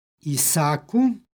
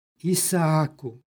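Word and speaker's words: Isaaku: Može se u ovome primjeru izgovoriti jedno duže a, Isaku, a može se izgovoriti i sa dva a, Isaku.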